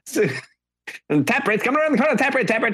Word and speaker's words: Taproot: Taprit. – Taprit. – Mm-hmm. – Are you? Do you not know how to say the band name Taproot? Taproot.